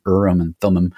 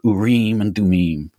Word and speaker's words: Urim and Thummim: You've got this ephod, and you've got Urim and Thummim, which is, you know, English pronunciation, Urim and Thummim. But man, it sounds so much cooler, Urim and Thummim, that way, right? – It is.